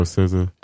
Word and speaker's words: SZA: It's Aza Asa or SZA.